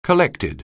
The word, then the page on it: collected: anglais